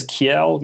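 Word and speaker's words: Kiell: I say it as Kiel. You might pronounce it as Kiel. It's K-I-E-L-L.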